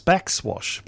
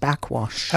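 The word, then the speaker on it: Backxwash: So it's Backswash because there's an X in the middle. – Yes, but it's pronounced Backwash.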